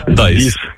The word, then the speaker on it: Dies: Так, тільки англійською дієс, як читається. От пишеться D-I-E-S. Dice.